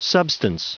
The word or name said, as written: substance